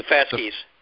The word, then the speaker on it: fasces: The fasces.